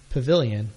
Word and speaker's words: Pvilion: It's called Pavilion, but they spell it P-V-I-L-I-O-N, as in photovoltaic.